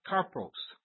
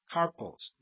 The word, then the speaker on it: karpos: So that word that's translated fruit in Galatians 5:22 is the Greek word karpos, karpos. It's transliterated, if you want to spell it, K-A-R-P-O-S, English.